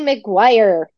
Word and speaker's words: McGuire: Well, I have Kelsey McGuire. Did I say that correctly? – Yes, you did.